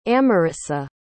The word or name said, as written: amarisa